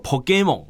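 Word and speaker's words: Pokémon: 我们这个日本如的用日文的发音叫做 Pokemon，好，那我们中文的翻译呢就叫做神奇宝贝，好，那这个宝可梦其实是它直接的音译啦，就是 Pokemon 的这个音译，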